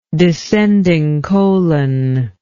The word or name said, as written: descending colon